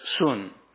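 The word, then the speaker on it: sun (σύν): It is spelt in English S-U-N and pronounced sun.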